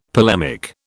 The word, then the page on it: polemic: British English